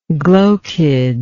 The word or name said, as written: glochid